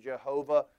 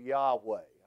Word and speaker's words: Jehovah: For that, I prefer in the English language the pronunciation Jehovah over the English derivative Yahweh.